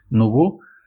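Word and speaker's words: nouveau: Cualquier distribución Linux que instale con los drivers libres de NVIDIA, los Nubeau, que nunca sabré cómo se pronuncia. – Nubeau. – Francés.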